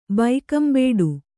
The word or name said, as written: ಬೈಕಂಬೇಡು